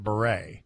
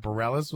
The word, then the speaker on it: Bareilles: And I had a boss in radio who said, no, we're going to pronounce it Beret. Because it was spelled, it's a French spelling, but an American singer that Bareilles is how it was. But it was the French pronunciation. And we were all like, what are you talking about? He's like, no, we're Canadian, so we'll do it Beret. And we're like, no, or she pronounces it Bareilles, we'll say Bareilles. That's how it works.